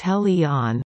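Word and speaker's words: Heliene: Helene, pronounced Hel-E-On,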